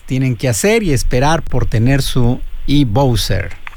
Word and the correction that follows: e-Boxer: E-Bowser, ¿no? Así se pronuncia. – E-Boxer. – Boxer. E-Boxer.